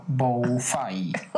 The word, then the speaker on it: Bouffay: Sí, Buffet se escribe B-O-U-F-F-A-Y, ¿eh? No se escribe Buffet como la... No, Buffay, más o menos.